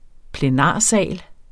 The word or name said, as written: plenarsal